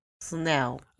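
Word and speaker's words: snel: Wait, how do you say schnell in Dutch? – Schnell. – Snell.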